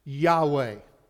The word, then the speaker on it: Yahweh: But today, most scholars agree that it's pronounced Yahweh, which means I am, or I the self-existing one.